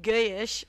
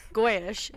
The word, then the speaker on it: goyish: You've denied that you pronounce it guyish, whereas Amanda and I in America would say goyish, as she said in the beginning of the pod.